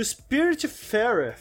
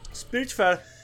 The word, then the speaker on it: Spiritfarer: Então a gente vai falar aqui do Battletoads, do Mortal Shell e do Spiritfarer. Falei certo, Lucas? – Falou certíssimo. – Spiritfarer.